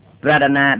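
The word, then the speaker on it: pradhāna: Pradana đọc là D dưới R A D H A N A là pradana. Prana là B dài R A N A,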